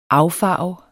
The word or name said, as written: AFFARVER